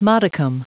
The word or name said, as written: modicum